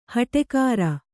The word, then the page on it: ಹಟ್ಟಿಕಾರ: haṭṭi kāra